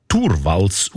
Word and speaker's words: Torvalds: Der Linus Torvalds, eigentlich Turwals ausgesprochen.